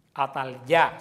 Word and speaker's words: Atalía: Atalía quiere decir Atalya, se pronuncia Atalya.